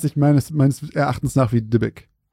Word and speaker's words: Dybbuk: Ich glaube tatsächlich, es wird Dibbuk ausgesprochen. Es schreibt sich halt D-Y-B-B-U-K.